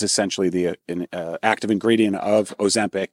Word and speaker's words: Ozempic: Everybody's taking Ozempic. Am I pronouncing it right? – Yeah, that's right. Ozempic is essentially prescribed for diabetes, but it's often used for weight loss off-label, and doctors are prescribing it that way.